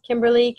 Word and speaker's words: Kimberley: It's Kimberly, K-I-M-B-E-R-L-E-Y, and Linert, L-I-N-E-R-T.